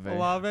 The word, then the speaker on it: Olave: If Olave is going to come in there, is that how you say his name? – Olave.